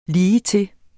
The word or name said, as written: ligetil